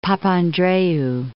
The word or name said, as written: Papandreou